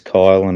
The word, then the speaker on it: Kiel: My name's spelled K-I-E-L, so half the times it's Kyle and the other half it's Kiel.